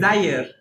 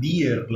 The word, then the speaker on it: Dyer: Creo que es Dyer la, la pronunciación, creo. – Porque, amén, corríjanme si estoy equivocado, pero en el en inglés la Y se pronuncia como I. Como I.